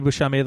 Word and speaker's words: Buscemi: And my name's Andy Buscemi. The last name is spelled B-U-S-C-E-M-I.